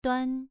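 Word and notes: duan: tone 2

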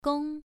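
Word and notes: gong: tone 1

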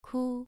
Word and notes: ku: 2

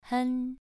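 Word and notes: hen: tone 2

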